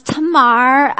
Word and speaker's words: Tamar: It's Tamar. I tell people it rhymes with Lamar.